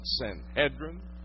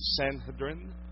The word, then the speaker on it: Sanhedrin: The word Sanhedrin, some pronounce it Sanhedrin, some pronounce it even Sanhedrin, either way, meant all sit together.